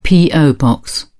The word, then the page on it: po box: British English